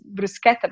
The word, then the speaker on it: bruschetta: Pasta, bread, bruschetta, anything you want. – Bruschetta, yes, you said bruschetta. In Japanese, we call it bruschetta as well, yeah? – Mm-hmm. – In a little bit more Japanese way. But in England, people... – It's bruschetta. – Bruschetta. Does it bug you? – Well, it does a little bit, because obviously the right pronunciation is bruschetta, but it's also quite sweet, let's put it that way.